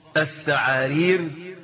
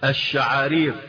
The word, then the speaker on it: الشعارير: عمرو اللي سنان وقعت، فنطقها الثعارير وهي تنطق بالشين. قال ايه؟ الشعارير. فهو بدل ما يقولها الشعارير علشان سنان وقعت نطقها ايه؟ الثعارير.